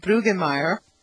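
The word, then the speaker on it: Brüggemeier: excuse my pronunciation, Brueggemeier, Brueggemeier, I-E-R, sorry.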